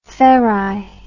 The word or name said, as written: ferri